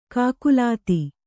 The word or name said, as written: ಕಾಕುಲಾತಿ